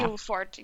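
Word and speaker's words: Ilfort: It was Ilford, right? – Yeah. – Ilford, yeah. Because that wasn't how I was going to pronounce it when I first saw how it was spelled.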